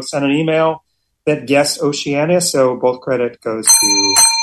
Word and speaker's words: Oceania: Five syllables clocking in at an astonishingly scant seven letters is the word Oceania.